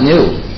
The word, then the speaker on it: GNU: And the reason is, according to the dictionary, the G is silent. So it sounds like new.